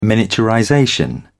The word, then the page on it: miniaturization: British English